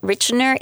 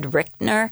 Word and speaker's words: Rychner: Now, Richner is R-Y-C-H-N-E-R, but thank goodness the book contained the pronunciation, which was Richner, because I think I would have probably done Richner, but yeah. It's Richner.